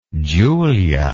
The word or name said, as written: julia